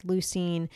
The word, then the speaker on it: leucine: There is something they're now making capsules with called L-leucine or leucine. It is spelled L-E-U-C-I-N-E.